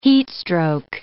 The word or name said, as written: heatstroke